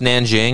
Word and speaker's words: Nanjing: I think this is pronounced Nanjing.